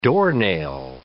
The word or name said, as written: doornail